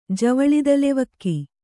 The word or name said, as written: ಜವಳಿದಲೆವಕ್ಕಿ